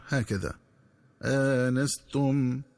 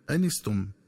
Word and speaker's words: آنستم: ينبغي التنبه للنطق الصحيح للفظ آنستم، فيقرأ بهمزة مفتوحة بعدها ألف مع فتح النون هكذا آنستم، ومن الخطأ قراءته بحذف الألف وكسر النون هكذا أنستم.